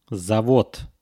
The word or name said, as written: zavod